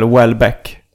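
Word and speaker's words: Houellebecq: Ja, vi har haft en um, uh, diskussion om i podden hur man uttalar uh, eh, Michel Welbeck. Men det ska mm. alltså vara som uttalas som ett... Nu har många skrivit in. Uh, och eh, det ska uttalas som ett W i början. Michel Welbeck.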